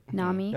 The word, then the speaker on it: Nami: Not Nami, is that how you say it? – Yeah. – Nami.